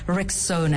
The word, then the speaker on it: Rexona: It's pronounced Rexona, and as this lovely Spanish man came to our rescue, Desodorante.